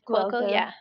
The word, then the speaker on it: Cuoco: It has Kaylee Cuoco in it. – Cuoco.